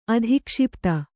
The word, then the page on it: ಅಧಿಕ್ಷಿಪ್ತ: adhikṣipta; Kannada